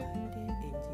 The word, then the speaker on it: 臙脂色: よろしくお願いいたします 2 月 5 日のテーマはエンジ色です文字は月変の横に古来中国で存在していた円という国の文字と死亡の死と書いてエンジ色となります